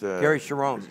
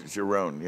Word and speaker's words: Cherone: How do you pronounce Gary Cherone. – Cherone, yeah.